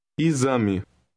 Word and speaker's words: exame: In word 5, the X is pronounced like a Z. Listen and repeat. – Exame.